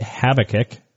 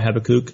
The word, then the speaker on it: Habakkuk: It was called Project Habakkuk. How do you pronounce that? – Habakkuk. – Oh. – There's a couple of different pronunciations. I usually call it Habakkuk. It could be Habakkuk.